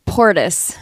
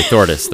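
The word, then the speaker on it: Þórdís: Like I thought it was Portis. – Portis. – But then I looked up the sound and it's actually pronounced.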